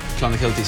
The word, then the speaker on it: Clonakilty: Irish tea bags or Clonakilty sausages from Cork. – Clonakilty.